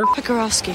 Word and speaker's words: Pekarovsky: Moira pronounces Lori's last name and places just the slightest bit of emphasis on the first half of that. – Pecker? A Pecker? – Pekarovsky.